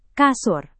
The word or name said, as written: kasur